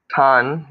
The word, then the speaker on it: Tan: Tan, like tan in the sun.